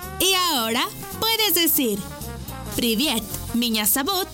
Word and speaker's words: меня зовут: Y se escribe P-R-I-V-I-E-T. Привет. ¡Muy bien! Repite conmigo. Привет. ¡Muy bien! En ruso, me llamo se dice меня зовут. ¿Y tu nombre? Una vez más, меня зовут. Por ejemplo, меня зовут. Esta palabra se escribe меня. M-I-N-A. Меня зовут. Z-A-V. U-T, sabut, miña sabut.